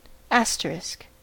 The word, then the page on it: asterisk: American English